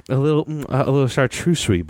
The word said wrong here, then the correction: charcuterie: Did I say that wrong? – Yeah, you did a different word in there. – Hey, Google, how do you say chartreuserie? Hold on. Hey, Mandy. Is it chartreuserie board? Charcuterie? All right. You win this one.